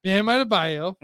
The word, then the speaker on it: Bam Adebayo: Bam Adebayo.